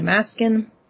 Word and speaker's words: Maskin: So I tell people, if you're curious about how to spell it and how to pronounce it, think of the word Alaskan, like you're from Alaska. Replace the L with an M, you get a Maskin. That's how it's spelled, that's how it's pronounced.